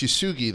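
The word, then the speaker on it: Yesugei: I'm going hard G. It's Yasugi then.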